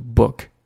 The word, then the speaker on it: book: OK，后面的 He's reading a book，我们又学到了这个 book，它的发音，嘴唇要放松啊，对吧？口腔要放松，读一个 b b 这样的感觉，book book 就可以了。